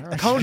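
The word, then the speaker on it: Qo'noS: Kronos.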